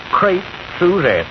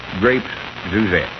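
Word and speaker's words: crêpes Suzette: Crepe Suzette. – Grapes Suzette.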